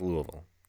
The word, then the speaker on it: Louisville: Because if you live in Louisville, Kentucky, it's pronounced Louisville. You have to slur it as much as you can.